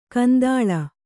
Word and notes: ಕೆಂದಾಳೆ: kendāḷe

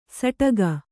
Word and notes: ಸಟಗ: saṭaga

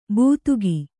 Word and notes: ಬೂತುಗಿ: būtugi

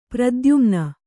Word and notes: ಪ್ರತೀಯಮಾನ: pratīyamāna

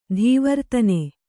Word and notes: ತವರ್ತನ: tavartana

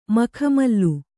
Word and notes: ಮಖಮಲ್ಲು: makha mallu; Kannada

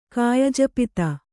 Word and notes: ಕಾಯಜಪಿತ: kannada

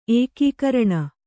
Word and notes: ಏಕಗ್ರಣಿ: ēkagraṇi

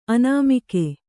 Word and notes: ಆನೆಮುಗ: Kannada

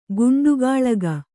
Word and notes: ಕುಂಡಗೋಲಕ: kuṇḍagōlaka

